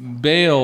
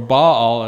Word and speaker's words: Baal: As early as the book of Leviticus, God addresses the practices in Canaan, which not just the worship of the so-called Baal or Baal, as you could pronounce it, but also of this other deity called Moloch, or also spelled Molech in some of your Bibles.